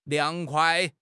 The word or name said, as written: liang2 kuai5